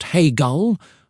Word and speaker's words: Hegel: Georg Wilhelm Friedrich Hegel, pronounced Hegel, was a German philosopher who lived from 1770 to 1831.